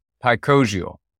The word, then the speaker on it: picosial: I came up with the word picosial. I'm going to spell that for you. P-I-C-O-S-I-A-L.